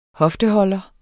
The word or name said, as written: hofteholder